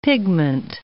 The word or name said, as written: pigment